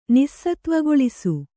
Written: ನಿಸ್ಸತ್ವಗೊಳಿಸು

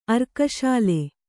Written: ಅರ್ಕಸಾಲೆ

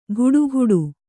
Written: ಗುಡುಗುಡು

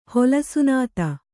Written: ಹೊಲಸುನಾತ

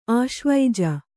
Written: ಆಶ್ವೀಜ

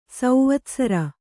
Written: ಸೌವತ್ಸರ

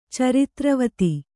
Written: ಚಾರಿತ್ರವತಿ

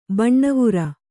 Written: ಬಣ್ಣವೇರು

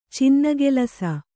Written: ಚಿನ್ನಗೆಲಸ

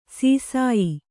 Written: ಶಸ್ಯ

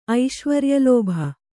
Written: ಐಶ್ವರ್ಯಲೋಭ

